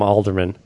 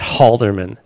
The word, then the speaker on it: Halderman: We'll just call him Alderman. You don't really pronounce the H on it, do you? It's not Halderman.